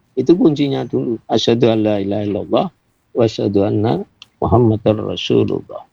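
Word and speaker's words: La ilaha illallah (لا إله إلا الله): Lamnya di depan harus dibaca panjang.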